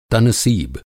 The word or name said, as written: तनसीब